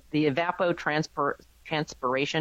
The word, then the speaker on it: evapotranspiration: I have a feeling that metering is still going to go on, because the evapotranspiration, am I saying that right? – Yes.